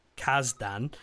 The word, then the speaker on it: Kasdan: Kasdan and written by Kasdan and co-producer Judd Apatow.